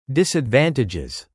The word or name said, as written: disadvantages